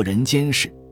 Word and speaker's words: 人间世: 一篇题目的结构也是人间世，不是人间事。很多人就理解错了，这个“ 间” 在这里是动词，就是我们说的介入，介入就叫“ 间”，是动词。人间世就是人介入社会，是指的是社会，指的是世界。那么。你们只要知道这个题目读作“ 人间事”，这一篇的意思就明白了一半。